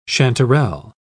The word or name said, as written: chanterelle